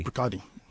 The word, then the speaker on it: Bruichladdich: That's BRUICHLADDICH. Brook Lottie. That's how they pronounce it? Brook Lottie? – Brook Lottie. Brook Lottie.